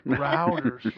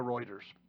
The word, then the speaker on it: Reuters: Reuters. – How many times do I say that wrong? – You say that wrong every time. – Just a couple of hundred, yeah. – Routers.